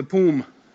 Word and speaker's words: POUM: POUM, the whatever the how you pronounce that. – The PUM.